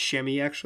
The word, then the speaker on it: Semi: He could be a nice, like, if you could just replace whatever Hezonja does with whatever Semi does. I think it's Shemi actually. I was pronouncing it wrong.